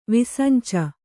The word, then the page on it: ವಿಸಂಚ: kannada